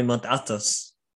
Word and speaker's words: Mount Athos: A, a and then, uh, and then Mount Athos in Greece, yeah. the monastery Mount Athos. If I say that correctly. Yes, Mount Athos, the holy mountain.